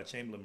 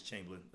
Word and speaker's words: Chamblin: I go by Chamblin. My brother goes by Chamblin. – So. Come on. – No, it just depends on how you say it, but I pronounce this Chamblin. That's okay. What it is. Yeah, even though it's spelled like Chamblin, we were raised as Chamblin, so that's what I go by.